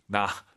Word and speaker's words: 那: 한국어랑 똑같아요, 발음이. 나. – 아, 那个 나. – 한국어에 있는 나잖아요. 너무 쉬운 발음이고, 정말 많이 씁니다.